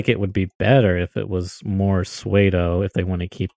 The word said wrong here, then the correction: pseudo: And is that how you say it? Suedo? Suedo? – I've always said pseudo.